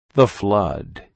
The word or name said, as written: Whiffler